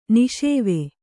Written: ನಿಷೇವೆ

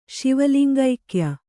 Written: ಶಿವಲಿಂಗೈಕ್ಯ

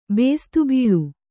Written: ಬೇಸ್ತುಬೀಳು

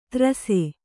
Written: ತ್ರಸೆ

